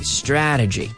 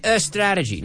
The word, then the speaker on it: strategy: Strategy. So be careful with the pronunciation. We don't say a strategy. No. Strategy. Your advertising strategy.